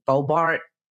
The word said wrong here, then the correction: Boebert: Is that it? Lauren Boebert? – Lauren Boebert.